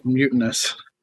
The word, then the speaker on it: Mutanus: So this was the card I was most excited about, was Mutanus. And I'm pronouncing it Mutanus. I'm guessing it's probably Mutanus or Mutanus. – Mutinous. – Mutinous. Okay.